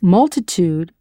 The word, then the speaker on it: multitud: So if multitude is multitud, with that soft D sound that's almost a TH in Spanish, what would aptitude be?